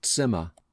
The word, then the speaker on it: Zimmer: Don't forget the TS sound in Zimmer.